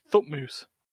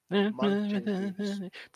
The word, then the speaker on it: Thutmose: Footmoose. – Sorry, Thutmoose. – Footloose? – Thutmose. – H-U-T. – But pronounced Thutmoose.